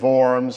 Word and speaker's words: Worms: It's one of the most unfortunate words to describe a diet, but the Germans pronounce it Worms, so the Diet of Worms, the Diet of Worms.